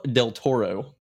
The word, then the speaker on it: del Toro: I also believe it's directed by Guillermo, del Toro.